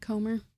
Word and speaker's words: Comer: Comer.